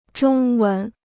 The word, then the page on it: 中文: zhōng wén